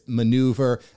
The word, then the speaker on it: ManeuVR: He's been an attorney for over 36 years, and he is now the co-founder of Manuvr, I guess is the way I pronounce it, but it's Manuvr, rebranding himself as the Metaverse attorney, spoken at Tony Robbins events.